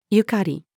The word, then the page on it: 縁: female